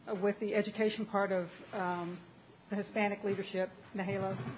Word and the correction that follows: NALEO: am I saying it right? – Naleo.